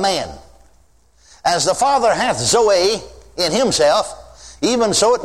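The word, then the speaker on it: Zoe (ζωή): It is pronounced Zoe. Now I'm not a Greek scholar. But the professor of Greek in Baylor University in Waco, Texas Baptist Seminary told me that that's the way you pronounce it, is Zoe.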